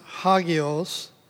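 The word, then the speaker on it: hagios (ἅγιος): Well, it is a Greek word that is pronounced hagios, and it means sacred, holy, set apart for or by God to be sacred.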